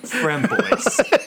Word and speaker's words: Framboise: I'm laughing at both of you. It's Framboise. And then you butchered it funnily, too.